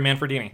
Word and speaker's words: Manfredini: Harry Manfredini.